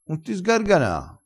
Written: sgargana

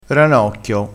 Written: ranocchio

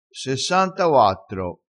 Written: sessantauattro

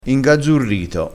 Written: ingazzurito

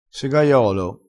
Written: segaiolo

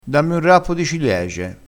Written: rappo

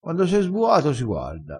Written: sbua

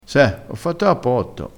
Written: fa cappotto

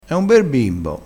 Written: bimbo

